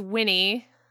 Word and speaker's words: Wenee: He calls us Winnie. That's how he pronounces it, which is really cute. It's spelled W-E-N-E-E, which you could totally say it's something different, but if he wants me to be that, then that's what I am.